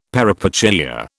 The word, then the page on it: peripeteia: British English